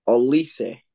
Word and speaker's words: Olise: How about Olise? Is that how you say it? – That's how you say it.